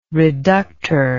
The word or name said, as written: reductor